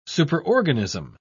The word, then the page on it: superorganism: British English